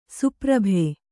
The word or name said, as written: ಸುಪ್ರಭೆ